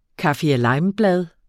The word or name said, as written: kaffirlimeblad